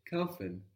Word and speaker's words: kaufen: Kaufen. – Bravo, kaufen. Kaufen. – And that's spelled K-A-U-F-E-N. Kaufen, which we can take from spätkauf.